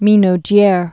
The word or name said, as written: minaudieres